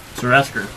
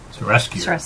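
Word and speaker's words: Serasker: His title is Sir Asker. – And we never got that. – That's what they call generals. – We just knew he was the leader of the military. – Right. – Sir Asker.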